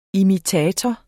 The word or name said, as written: imitator